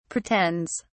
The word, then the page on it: pretends: British English